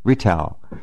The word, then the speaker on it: Rittal: And then it says a documentary film by Günter Rittal. Is that how you pronounce it? Or Rittal? R-I-T-T-A-L. Rittal.